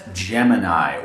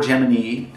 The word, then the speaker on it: Gemini: How would you actually pronounce this properly? – Planet Gemini, or Gemini, depending on where you live in the world, apparently.